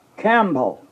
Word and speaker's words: Campbell: Angus Campbell. Actually, they pronounce it almost like camel.